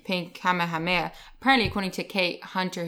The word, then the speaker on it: Kamehameha: They would go Kamehameha and they'd shoot off their lightning bolts. So that's how she told me how to pronounce it, because it is a tongue twister.